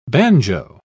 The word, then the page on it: bagnio: British English